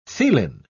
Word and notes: theelin: British English

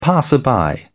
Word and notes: passer-by: British English

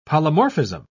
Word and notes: polymorphism: British English

